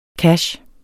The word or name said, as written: CASH